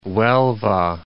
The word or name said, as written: Huelva